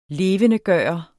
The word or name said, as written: LEVENDEGJORTE